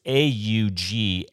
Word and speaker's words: AUGMENTT: And just so in case of those of you who are phonetically challenged, it's AUGMENTT. Is that, did I get that right? – That's absolutely correct.